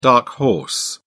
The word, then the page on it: dark horse: British English